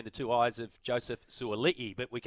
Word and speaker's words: Suaalii: which is exactly what Daniel Tupou and Joseph Suaalii, which we now can confirm is the pronunciation, right? – Yes. – Suaalii.